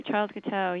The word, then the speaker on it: Charles Guiteau: That's right, Charles Guiteau, yeah.